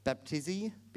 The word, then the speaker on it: baptizo: The Greek word is baptizo. Baptizi? I don't know how to say it. Baptizo.